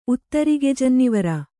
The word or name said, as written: ಉತ್ತರಿಗೆಜನ್ನಿವರ